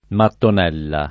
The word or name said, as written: mattonella